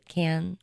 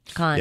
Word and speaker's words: Cannes: you pronounce it? Can? – Can. Can. Can. – Con.